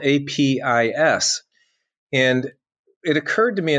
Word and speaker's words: Apess: Um, so uh, that that's that's I, I, I, could have gone different ways, but I, I still say apis, and, and I know there are others who who would go a different most, route with that. Yeah, most scholars that I've heard pronounce it apis.